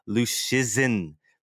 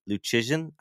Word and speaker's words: Luszczyszyn: because I don't think there's I think it's Luszczyszyn. I don't think there's any H in it at all, even though it seems like there should be like an H sound, like Chizin, Luszczyszyn.